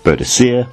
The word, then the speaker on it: Boudicea: Of course, there are two pronunciations of her name, Boudicea, which is what I was taught in school, and Boudica, which has become more and more prevalent in recent years.